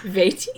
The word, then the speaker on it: Vaati: Vaati? – The weekly show on Wii's Nintendo channel has confirmed his name is pronounced Vaati on its September 29th, 2011 broadcast.